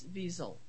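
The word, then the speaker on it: Wiesel: It's like with a Z sound, not an S. The way he did it, but it's not Zell; it's Weasel. – Okay. – You know. The accent is on the first syllable, not the second.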